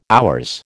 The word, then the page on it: HR: American English